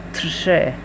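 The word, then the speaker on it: trzy: عدد بعدی عدد سه هست که میشه ترشه، ترشه. تلفظش کمی سخته، اول جو کنیم ترشه، ت در اول از ترشه.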